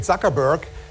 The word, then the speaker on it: Zuckerberg: Zuckerberg is such a traditional, wonderful German-Jewish name that pronouncing it Zuckerberg sounds for us Germans like for you Americans if we would say instead of Bruce Springsteen, Bruce Springsteen.